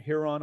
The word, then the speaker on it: Huron: and Her- Huron.